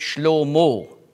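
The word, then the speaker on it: Shlomo (שלמה): Auf Hebräisch spricht man aus Shulamit und Shlomo.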